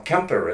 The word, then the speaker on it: Quimper: Well, no, Kemper is how they pronounce it. Okay. Yeah, because of Breton.